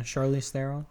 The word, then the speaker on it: Charlize Theron: How do I say this? Charlize Theron? – Yeah, Charlize Theron.